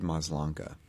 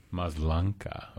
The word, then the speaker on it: Maslanka: By David Maslanka. – Maslanka.